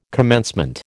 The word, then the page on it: commencement: American English